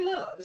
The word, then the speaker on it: Lillard: It's Matthew Lillard.